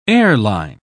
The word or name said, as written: airline